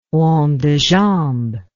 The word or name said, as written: rond de jambe